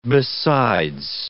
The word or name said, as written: besides